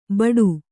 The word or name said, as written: ಬಟು